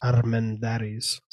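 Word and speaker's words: Armendáriz: All right, so a video on YouTube says that his name is pronounced Armendariz. So Pedro Armendariz.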